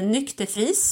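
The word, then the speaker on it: Nyck de Vries: Och anledningen till det är ju att Mercedes har gjort sig av med både sin testförare Nykte Friis, som blir förare för Alfa Tauri nästa år.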